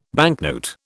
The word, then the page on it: banknote: British English